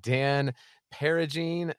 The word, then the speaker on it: Peragine: Well, I am joined here by Dan Peragine, pronounced like pair of jeans. – Pair of jeans. Yep. There you go.